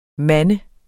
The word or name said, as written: mand